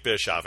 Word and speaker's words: Bischoff: Yep, that's how you say his name, Jake Bischoff. Not that anyone's going to mess with that, but I'm using the pronunciation guide on the U site so everyone knows what their names sound like.